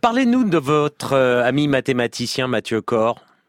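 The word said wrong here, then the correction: Cord: Oui, alors Mathieu Corde, qui qu'on prononce son nom Corde, C-O-R-D, c'est un exemple, voilà, je prenais un exemple dans ces accidents de collision.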